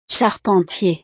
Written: charpentier

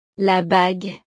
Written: la bague

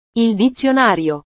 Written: il dizionario

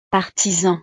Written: artisan

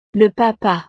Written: le papa

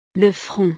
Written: le front

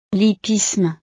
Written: l'hippisme

